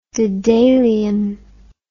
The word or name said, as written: dedalian